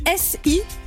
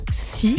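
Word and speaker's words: si (四): Non mais ça s'écrit S I. Ça pourrait être si, si, par exemple, ouais. Voilà. Et en fait, c'est exactement pareil, 4 et la mort.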